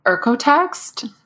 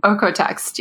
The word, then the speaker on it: OEKO-TEX: We talked about, I don't know if you, do you say it or do you like say in each letter, the O-E-K-O-T-E-X? – Yeah, it's pronounced apparently Ercotext, which is not intuitive, but yes. Ercotext, yeah. – That's like not what it looks like. – I know. I was pronouncing it wrong for years. And then I met them at a trade show and they like said Ercotext.